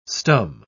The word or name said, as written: stum